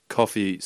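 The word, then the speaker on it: Coffey: Coffee spelt with a Y instead of two E's, like the drink.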